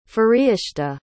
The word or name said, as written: fariishta